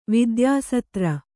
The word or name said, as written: ವಿದ್ಯಾಸತ್ರ